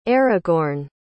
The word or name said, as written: Aragorn